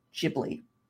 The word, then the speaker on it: Ghibli: Yes, it's not the correct pronunciation of the Italian word. Just consider it the Italian word, like, inspiration for Miyazaki, and that he decided, I don't know, to continue running with his mispronunciation for the last 30, 40, 50 years of his life. It's Ghibli.